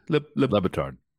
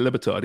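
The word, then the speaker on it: Le Batard: How do you pronounce his name again, B? – Lebatard. – Yeah, Lebatard, yeah. He was talking about it and how,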